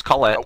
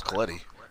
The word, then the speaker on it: Collette: It's just Collette. – Oh, Collette? I thought it was Colletti. – Nope. – Oh, the way it's pronounced is Collette? – Yep.